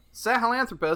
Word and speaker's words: Sahelanthropus: Seth tells you about the weapon that you need to kill the Lord of Dust, the Sahelanthropus. – Yep. – Sahelanthropus.